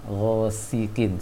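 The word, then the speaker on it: ghasiqin (غاسق): Kemudian pengucapan sin sedikit ghasiqin seperti baca tha tadi, ujung lisannya ke atas, seharusnya ujung lisannya ke bawah, ghasiqin seperti baca s.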